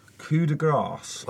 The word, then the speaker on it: coup de grâce: It doesn't end with an S. So it's not silent. It's grace. It's coup de grâce.